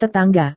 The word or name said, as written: tetangga